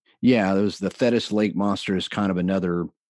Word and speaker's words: Thetis: Is it Thetis Lake? – Yeah, Thetis Lake.